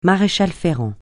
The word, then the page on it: maréchal-ferrant: francais